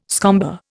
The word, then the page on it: scomber: Indian